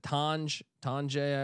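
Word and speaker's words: Tonje: John Tanjay. Yep. How do you pronounce it? Tanjay. I always forget.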